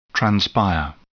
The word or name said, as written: transpire